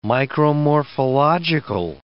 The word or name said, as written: micromorphological